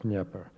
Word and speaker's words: Dnieper: It was freezing cold, but the wind was down on the, in Russian, I'm pronouncing it in the Russian way, it's called Dnieper. It's spelled Dnieper, but it's really pronounced Dnieper.